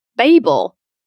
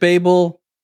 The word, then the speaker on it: Babel: I say Babel, which I think is a UK way of pronouncing it. – I think most Americans say Babel. I've heard it pronounced Babel, oh, really? – Babel. The funny thing is I actually went to see how is it supposed to be pronounced. And I think the actual proper Hebrew pronunciation is Babel.